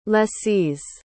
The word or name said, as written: lessees